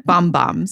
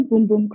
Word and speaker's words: Bum Bum: And thank you for pronouncing it correctly. We call it the Boom Boom Cream, the Brazilian Boom Boom Cream, because boom boom means butt in Portuguese.